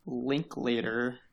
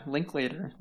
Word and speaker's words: Linklater: Boyhood is a film that came out in 2014 that was written and directed by Richard Linklater, which I believe is how you pronounce his name. I've never tried to say it out loud before.